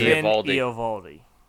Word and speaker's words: Eovaldi: Say his name, because you know I'll butcher Eovaldi.